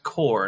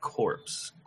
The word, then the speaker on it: Corps: It's pronounced core, not corpse. Corpse is something you become when somebody shoots you.